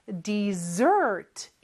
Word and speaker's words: dessert: 영어로 디저트라고 하는데 발음이 조금 달라요. 그리고 스펠링 잘 보세요. S가 두개 있습니다. Dessert. 이렇게 뒤에 강세가 있고요.